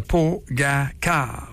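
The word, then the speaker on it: Pogačar: Trajet du Pogacar. On prononce ça comme ça, ouais? Oui.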